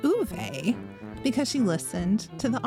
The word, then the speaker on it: Ove: Yeah, but she said she listened to the audiobook and it was Uve.